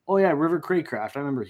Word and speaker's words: Cracraft: It is Cracraft.